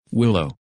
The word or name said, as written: Willow